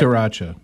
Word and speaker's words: Sriracha: But sriracha.